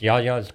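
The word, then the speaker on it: Yia's: And the name is actually pronounced Yaya's Greek Express, even though it's spelled Y-I-A apostrophe S. That is the Greek way to spell Yaya.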